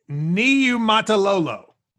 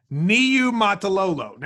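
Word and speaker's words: Niumatalolo: His first name's Ken. How do you say his last name? – Let me stare it down just for a brief second here. Okay. Niamatalolo. Niamatalolo. – It's Niamatalolo. Six syllables.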